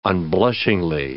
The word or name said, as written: unblushingly